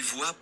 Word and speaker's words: voit: That means that she, meaning Lynn, sees, which is voit with a T, but you don't pronounce that because it's French and they don't fucking pronounce anything.